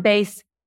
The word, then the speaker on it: Beis: Base, it's pronounced Base, B-E-I-S, is offering our listeners 15% off.